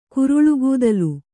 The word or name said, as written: ಕುರಳ್ಗೂದಲ್